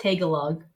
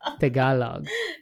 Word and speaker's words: Tagalog: It's not Tagalog. – It's Tagalog. – Yeah, no, you pronounced it really well.